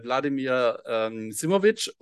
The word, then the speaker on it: Vladimir Simovic: Ja, also vielen Dank, deinen Namen hast du richtig ausgesprochen, Wladimir Simovic.